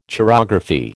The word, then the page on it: chirography: American English